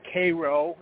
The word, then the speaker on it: Cairo: Now, something also interesting is that in the Memphis, Tennessee area, and Cairo, or Cairo is how they pronounce it, Illinois, the reason that area is called after Egyptian names is because they found Egyptian artifacts in that area.